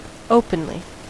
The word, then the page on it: openly: American English